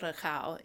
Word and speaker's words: recaito: Well, we pronounce it recao in Cape Verdean culture, but it's spelled R-E-C-A-I-T-O.